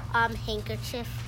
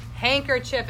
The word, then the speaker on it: handkerchief: Handkerchief. – Handkerchief, and what letter did you forget in the word handkerchief? – A silent D.